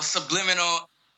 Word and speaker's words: Sublimental: All right, well, Sublimental sounds like Sublimental, the word, but it's spelled like Sublimental, like ends with the word mental, like from your mind and shit.